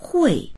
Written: hui4

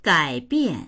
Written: gai3bian4

